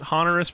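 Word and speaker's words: Honorius: Frederick II is crowned emperor by Pope Hornius. – Hornius. Okay. Well, we don't even want to know he's horny for. – Okay, it's actually pronounced Honorus, but we're going to call him Hornius.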